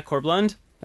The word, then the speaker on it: Corb Lund: So this is Corb Lund, yes? – Yeah, Corb Lund.